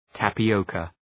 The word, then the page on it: tapioca: ,tæpı’əʋkə